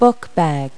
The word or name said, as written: bookbag